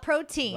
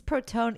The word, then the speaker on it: protein: So now at the house, we call it, oh, Mom, is this protone? Even though we know it's not how you say it, that's how we say the word now.